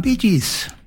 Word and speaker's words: Bee Gees: Og så har du at du skriver det slik som du uttaler det, så ble det Bee Gees.